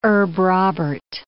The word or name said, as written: herb Robert